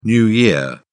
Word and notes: new year: British English

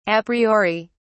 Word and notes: Apriori: British English

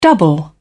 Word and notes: double: British English

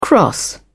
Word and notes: cross over: British English

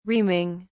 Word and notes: reaming: British English

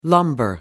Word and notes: lumbar: American English

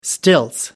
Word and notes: stilts: British English

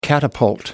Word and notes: catapult: American English

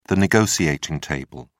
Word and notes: negotiating table: British English

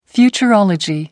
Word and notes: futurology: American English

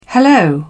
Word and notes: hello: British English

